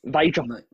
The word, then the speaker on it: Vydra: Vydra.